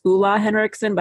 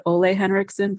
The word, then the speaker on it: Ole Henriksen: It's pronounced Ola Henriksen, but I call it Ole Henriksen.